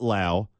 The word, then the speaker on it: Lowe: It's spelt low, but it's pronounced Lau.